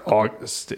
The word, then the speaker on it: August: The word August is pronounced with the accent on gust.